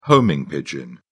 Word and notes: homing pigeon: British English

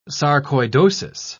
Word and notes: sarcoidosis: British English

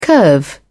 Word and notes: curve: British English

